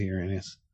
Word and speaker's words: Uranus: Yeah, it's like calling Uranus when they go, Uranus. – It is Uranus. – It's Uranus. – Uranus. – Uranus. It's spelt that way, but it's Uranus.